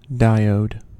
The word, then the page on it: diode: American English